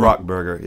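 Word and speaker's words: Rock Brgr: Rock, B-R-G-R. How do you pronounce it? Do you know? – It's Rock Burger, yeah.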